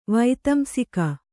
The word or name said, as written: ವೈತಂಸಿಕ